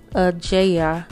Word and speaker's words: Ajayi: So, um, and his middle name is I want to say Ajaya. Ajaya. Um, so that's spelled A J A Y I, right?